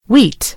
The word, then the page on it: wheat: American English